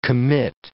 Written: commit

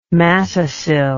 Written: matacil

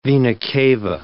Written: vena cava

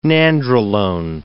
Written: nandrolone

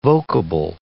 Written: vocable

